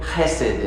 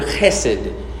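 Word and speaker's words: chesed (חסד): It's the Hebrew word chesed, and it's spelled H-E-S-E-D. But it's my favorite word for the love of God in the Old Testament. It's the Hebrew word chesed, H-E-S-E-D.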